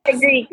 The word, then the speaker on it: fenugreek: You also send me the Amazon Intense Fenugreek. I don't know if I'm saying that right. I'm going to have to practice that. – Fenugreek, yeah.